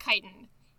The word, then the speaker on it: chitin: Another distinction between mushrooms and plants is that mushrooms make their cell walls out of chitin. It's pronounced chitin. – Yes. – It really looks like it should be kitten.